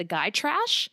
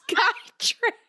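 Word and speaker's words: Gytrash: folklore where she thinks of this creature called, and I believe it's pronounced the guy trash, which is like kind of what Rochester is. Just guy trash.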